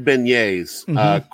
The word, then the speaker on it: beignet: The beignets.